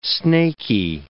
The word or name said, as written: snaky